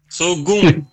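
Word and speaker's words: Saulgond: Saugon. – Saugon, ça s'écrit comment? – S-A-U-L-G-O-N-B. – S-A-U-G-O-L-B? – G-O-N-D.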